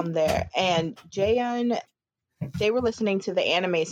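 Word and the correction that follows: Aminé: It was Amine. That's his name.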